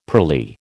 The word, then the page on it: pearly: American English